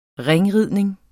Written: ringridning

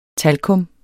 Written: talkum

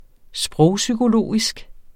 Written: sprogpsykologisk

